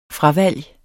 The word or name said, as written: fravalg